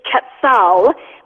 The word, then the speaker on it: Quetzal: And it's actually pronounced, now Tim is really funny here because we have differences in how we pronounce things, but it's Quetzal, which okay.